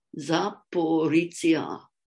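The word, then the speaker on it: Zaporizhzhia: And so let's not say that it's too difficult to pronounce the word Zaporizhia. Zaporizhia.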